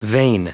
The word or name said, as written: vein